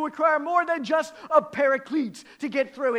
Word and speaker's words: paraclete: The term is parakletos, which transliterates into English and is often pronounced as paraclete.